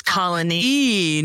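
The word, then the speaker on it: Colonie: Right, did I say it right? – You did. It's not Colony. – It's Colony.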